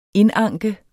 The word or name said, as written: indanke